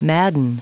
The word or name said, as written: madden